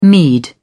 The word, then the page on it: mead: British English